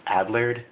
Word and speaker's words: Adlard: I believe his last name is pronounced Adlard, A-D-L-A-R-D.